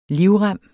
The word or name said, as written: livrem